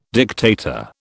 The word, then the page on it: dictator: British English